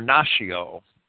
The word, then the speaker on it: natio: And the word nation comes from this Latin word, natio, or natio. And I would think that the ancient Romans pronounced it natio, with a hard T.